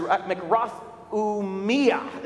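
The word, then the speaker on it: makrothumia (μακροθυμία): It's makrothumi, or actually makrothumia is the word. You got to put the emphasis on the me, the makrothumia.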